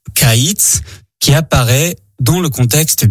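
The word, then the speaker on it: kaïtz (קיץ): Le mot pour dire été en hébreu est kaïtz. Kaïtz qui s'écrit de la manière suivante, kof, yod, tsadé sofit, le long, tsadé de fin de mot, kaïtz.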